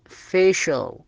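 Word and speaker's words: facial: الكلمة اللي بعدها فيشل، السي نطقناه بحرف الشاء، السبب مثل ما ذكرنا أتى بعد حرف آي أي، هذا من شروط السي ينطق حرف شاء إذا أتى بعده آي أي أو آي أو، هنا فيشل.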